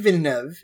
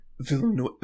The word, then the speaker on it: Villeneuve: Uh, okay, Denis Villeneuve is how we pronounce it here. – Villeneuve.